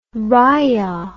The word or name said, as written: riah